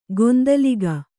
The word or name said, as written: ಗೊಂದಲಿಗ